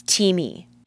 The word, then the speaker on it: Teami: It is Teamy. Y'all, I am very picky about who I partner with, and I am beyond obsessed with Teamy. It's spelled T-E-A-M-I, and I'll link up in the show notes, but right now, I have been literally drooling over their butterfly beauty collagen.